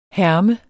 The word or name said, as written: herme